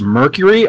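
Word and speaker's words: Mercari: I got one called Let Go, one called OfferUp, and one called, I guess it's pronounced Mercury, although you wouldn't know that by the way it's spelled.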